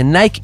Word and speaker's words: Nike: Hay gente que dice Nike, pero es Nike. – La mayoría de la gente que yo conozco dice Nike y no.